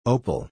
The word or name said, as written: opal